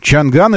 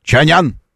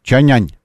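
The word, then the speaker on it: Чанъань: В соответствии с правилами китайской и русской транскрипции, по-русски это называется, название читается Чанян. Ань, Чанянь, Чанянь. Вот как мы должны произносить этот автомобиль.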